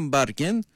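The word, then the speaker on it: Bergen: Χόλαντ προφέρεται, όπως επίσης δεν είναι Μπέρκεν η πόλη, είναι Μπάρκεν.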